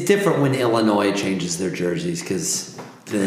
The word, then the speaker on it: Illinois: Well, you enjoy Illinois. I put an S on it. – No, I mean, there is an S on it. It's just not pronounced.